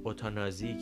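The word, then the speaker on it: euthanasie: پس یوتنیژه یا یوتنیزیه انگلیسی هستن و اوتانازی که ما میگیم اوتانازی اگر من درست تلفظ بکنم بر صورت به فرانسه اون چیزی هستش که معمولا شایع هستش و پزشکا و افراد ازش سخن به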